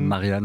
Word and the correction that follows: Martina: Martina Navratilova, effectivement. Voilà pour le prénom. Écorché, mais c'est pas notre époque.